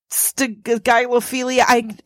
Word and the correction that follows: stygiophilia: Am I pronouncing it right now? – Not even close. Stygiophilia? – Stygiophilia.